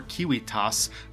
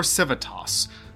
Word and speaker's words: civitas: This derives from the Latin word civis, or civitas, which are supposed to be pronounced kiwis or kiwitas, but no one pronounces them that way.